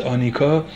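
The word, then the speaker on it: anicca: این اصلیه که در آیین بودا به نام اصل انیکه یا آنیکا ازش یاد میشه، A-N-I-K-A، اصل انیکه تلفظ سانسکریتش است، آنیکا به انگلیسی گفته میشه.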